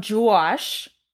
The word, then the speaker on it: Juosh: This one is from Juwash. That's how it's spelled. J-U-O-S-H.